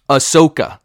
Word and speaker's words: Ahsoka: The one thing that I really, really liked about Rosario Dawson playing Ahsoka Tano, Ahsoka Tano, however the fuck you pronounce it, I'm so bad at the name, Ahsoka, sorry, had to say it correctly, is that she wanted this role.